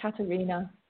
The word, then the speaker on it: Katerina: Katerina. – Katerina? Okay. – Yeah. – You know, there's another way of spelling it. C-A-T-R-I-O-N-A.